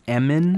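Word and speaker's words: Emin: And with this mogul's son, Eamon, am I pronouncing his name right? – I think it's Eamon. – Eamon, okay.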